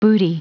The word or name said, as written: booty